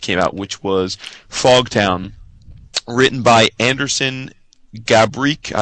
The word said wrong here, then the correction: Gabrych: I don't know if I'm saying his name right. It's Gabrych.